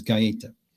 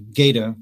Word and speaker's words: Gaeta: Well, the Italian pronunciation, which would be technically correct, is Gaeta. – Gaeta. Okay. – The American pronunciation is simply Gaeta, like the word gate with an A at the end. And I'm fine with either.